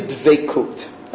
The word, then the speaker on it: dvekut: The goal of Judaism is dvekut.